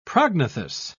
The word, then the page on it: prognathous: British English